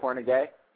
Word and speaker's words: Kornegay: The Macsata, what is Kornegay. – It? Kornegay? – Mm-hmm.